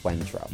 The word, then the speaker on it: Cointreau: It's spelled Cointreau, but it's French, so I think it's pronounced like Cointreau.